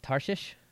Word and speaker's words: Tarshish: And I think I said that town wrong. – What, Tarshish? – Did I say it right? – Yes.